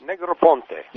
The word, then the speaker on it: Negroponte: Negroponte. – Yes, Mr. Negroponte.